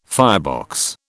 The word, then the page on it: firebox: British English